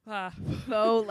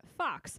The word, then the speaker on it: faux: So the word faux fur or faux leather, F-A-U-X, which she was pronouncing fox. I would like to know how you thought foxes made leather. – No, fox, like, just, I was sounding it out. Fox. F-A-U-X.